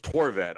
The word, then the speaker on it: Horvat: It's just Horvat. I don't know what you were going for the first time.